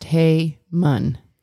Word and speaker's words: Haman: To insert here, per Google, it is pronounced Haman. – Haman. Haman. – Like Haman.